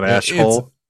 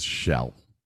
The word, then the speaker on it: Aschell: Not Ashiel. – It's spelled A-S-C-H-E-L-L, but the pronunciation is Shell.